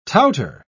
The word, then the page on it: touter: British English